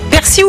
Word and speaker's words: persil: Alors normalement c'est persil, ok, ouais. D'après le dictionnaire de l'Académie française. Mais le nord et le sud du pays prononcent quand même le L, donc t'as le droit quand même aux deux.